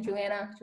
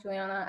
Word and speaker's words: Juliana: No matter how you say my name, Juliana, Juliana. I actually prefer that. I actually say to people, you can call me that because it sounds fancier.